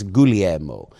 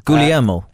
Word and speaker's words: DeGuglielmo: which when you see that Dave DeGuglielmo, Guglielmo. I don't think you pronounce the G. I think it's Guglielmo. – Guglielmo.